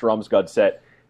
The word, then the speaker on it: Strømsgodset: Hasn't done it since August 8th through August 25th when he was playing for, and, you know, sorry for my pronunciation advanced, Strømsgodset in Norway.